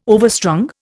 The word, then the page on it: overstrung: Indian